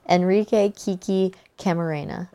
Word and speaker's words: Enrique Kiki Camarena: Enrique Kiki Camarena.